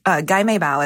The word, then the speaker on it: gai mei bao: So there's a lot of like theories about how cocktail buns or gai mei bao is how you say it in Cantonese.